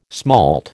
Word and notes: smalt: American English

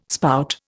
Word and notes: spout: Indian

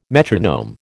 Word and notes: metronome: American English

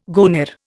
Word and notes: goner: Indian

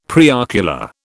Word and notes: preocular: British English

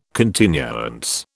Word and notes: continuance: British English

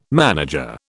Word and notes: manager: British English

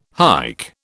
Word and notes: hike: British English

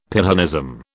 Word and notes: Pyrrhonism: British English

